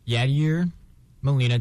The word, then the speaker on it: Yadier Molina: Yadier Molina.